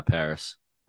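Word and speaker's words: PVRIS: Paris? – Yeah, Paris.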